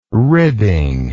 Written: ribbing

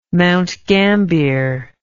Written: Mount Gambier

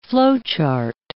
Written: flowchart

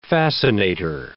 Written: fascinator

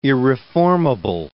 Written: irreformable

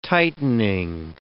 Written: tightening

